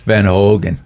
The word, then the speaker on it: Van Hoogen: My last name's Van Hoogen, and of course, when I started pastoring in a Dutch congregation, I realized I don't even pronounce my name correctly. – Yeah. – It's like Van Hoogen.